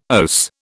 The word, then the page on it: OAS: British English